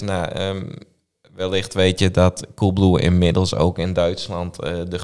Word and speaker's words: Coolblue: Hoe spreek je dat in het Duits uit? – Coolblue. – Gewoon hetzelfde.